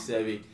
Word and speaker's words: Seby: My name is Sebi. You can follow me on S-E-B-Y underscore B-A-S-S.